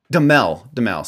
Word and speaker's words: Duhamel: It's Josh Duhamel. I think that's how you pronounce his name. – Oh. – Duhamel, sorry.